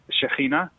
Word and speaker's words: Shekinah: The KH is like a H sound, you know. So it's Shekinah.